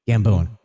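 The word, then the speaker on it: Gambon: So Michael, is it Gannon? So Gambone? – Michael Gannon? – Gambone. – Gambone? – Yeah. – Oh yes, I'm sorry. I said it wrong.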